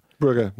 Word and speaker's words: Brugge: How do you pronounce it? Brugge. – Brugge, yeah.